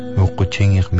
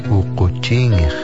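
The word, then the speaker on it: uuquchiingix: Ukuching means blue fox, and it is spelled U-U-Q-U-C-H-I-I-N-G-I-X. A person might say, Ukuching aigagiko. The blue fox is walking. That is today's Aleut word of the day. Ukuching. Ukuching.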